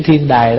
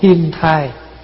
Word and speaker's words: Thiên Đài: Đúng ra là mình đọc Thiên Đài, nhưng mà cái chữ Thiên Đài đó khi qua tới Việt Nam là chư vị tổ của mình đọc là Thai. Vì vậy chữ Đài với chữ Thai nó phát âm, cho nên chúng ta thường gọi là Tông Thiên Thai. Nhưng mà thật sự nếu mà đọc đúng cái chữ là Tông Thiên Đài Trí Giả Đại Sư.